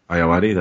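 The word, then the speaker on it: Ayoade: Iowati. That's how you pronounce it. Okay. – I believe I believe it is Iowati.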